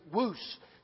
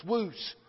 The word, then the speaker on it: Wuse: Let's see, Shelby Walls, her nickname is Woos. It's spelt W-U-S-E, but you pronounce it like moose, woos, woos.